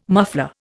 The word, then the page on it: muffler: Indian